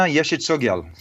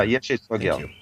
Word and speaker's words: Yeshe Tsogyal: So these were places, if I recall correctly, Padmasambhava had shown Yeshe and after, or Yeshe Tsogyal. Do you know the pronunciation of that? Am I getting it right? – Yeah, no, Yeshe Tsogyal. – Oh, good. – Yeah, Yeshe Tsogyal.